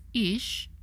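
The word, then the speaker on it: ish (איש): In Hebrew, the word for fire is that Hebrew letter pronounced as esh, while the word for man pronounced as ish, and the word for woman is pronounced as isha.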